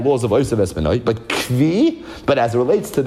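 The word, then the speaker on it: Kvi (כוי): So some people pronounce it as Koi. Yeshiva, it's a Kvi. So, Vashteitzach, we're going to call it a Kvi.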